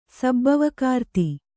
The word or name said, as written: ಸಬ್ಬವಕಾರ್ತಿ